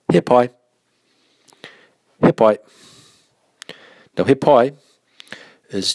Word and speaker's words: he pai: Now, be careful with your pronunciation of this of the word he pai. So, he is a real short saying. A lot of times you'll hear people say here, like